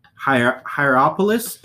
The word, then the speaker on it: Hierapolis: But there's two nearby cities, ancient cities called, and I probably am going to destroy these names, but it's Hierapolis and Colossae. – You said it right.